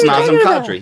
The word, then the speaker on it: Nazim Kadri: Nazim. Is that how you say it? Nazim. – I don't know. – It's Nazim Kadri.